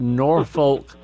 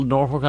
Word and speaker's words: Norfolk: I know that Norfolk sounds like Norfolk, Virginia, and it's spelled the same, but Norfolk Island is actually an island in the warm Pacific waters near Australia and New Zealand, I think.